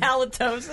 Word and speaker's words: halitosis: Hashtag Hashtag holiotisis. How do you say that word? – Halitosis.